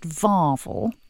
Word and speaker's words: vervel: Now, remember a couple of weeks ago, we were talking about shards versus sherds, the pronunciation, and I mentioned something that's spelt vervel, but it's pronounced varvel.